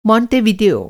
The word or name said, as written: मोण्टेवीडियो